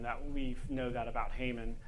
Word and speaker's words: Haman: Rhymes with Raymond. – Yes. It's Haman.